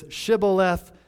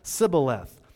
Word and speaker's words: Shibboleth: That's kind of what's going on here with this Shibboleth, Sibboleth. And so the Ephraimites did not pronounce shah. They pronounced it sa.